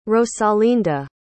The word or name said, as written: rosalinda